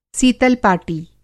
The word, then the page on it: सीतल-पाटी: Hindi